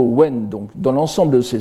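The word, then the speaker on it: wen (文): c'est-à-dire le wen ici, donc qu'on peut prononcer en japonais bun ou mon,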